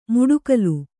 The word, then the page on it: ಮುಡುಕಲು: muḍukalu